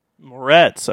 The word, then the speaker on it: Moretz: Next we have Chloe Grace Moretz. – Moretz. Okay, that's how you say it.